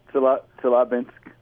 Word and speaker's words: Chelyabinsk: Chelyabinsk.